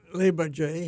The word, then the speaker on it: Le Bourget: Well, on the very first pass, when we went down to Le Bourget, is the way I pronounce it. – Mm-hmm. Or the French might say Le Bourget. – Le Bourget, yes.